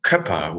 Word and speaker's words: Köpper: It's Köpper with an Ö, but that's very specific German, so might be difficult for you guys.